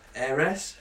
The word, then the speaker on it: heiress: Well, new word names for girls included allure, paradise, pristine, happiness, heiress. – Heiress. – Heiress. – Heiress. Yeah, silent H.